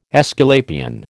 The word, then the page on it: Aesculapian: American English